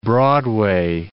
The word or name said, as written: Broadway